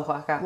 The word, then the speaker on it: Oaxaca: It's W-A-H-A-C-A. – And how do you really spell Oaxaca? – Oaxaca. O-A-X-A-C-A.